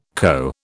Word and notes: co: British English

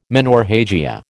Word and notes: menorrhagia: American English

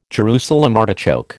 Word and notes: Jerusalem artichoke: American English

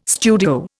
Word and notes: studio: Indian

